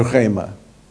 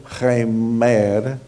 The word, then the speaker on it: rema: A palavra é rema. Não é remer, como algumas pessoas pronunciam a minha, meu nome. Não é remer, é rema.